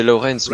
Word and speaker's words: Lawrence: C'est dès que j'ai reçu le mail, Madame Laurence, oui, Jennifer Laurence. – Ça se prononce pas comme ça Oh, euh, c'est Lawrence.